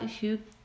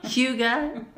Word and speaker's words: Hygge: So, anything else about Hygge? Oh, I was gonna say it wrong. Hygge, that we didn't talk about.